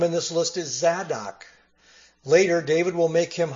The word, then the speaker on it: Zadok: That word Sadducee, it's from the Hebrew word Zadok, which in Hebrew is pronounced Sadok, and it's kind of like the Greek form we find then, Sadducee.